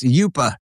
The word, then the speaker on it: UPA: All right, this guy says the UPA is pronounced Yupa.